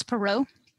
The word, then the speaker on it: Perrault: or even like Perrault or whatever. How do you pronounce that name? – Charles Perrault. – Perrault, okay.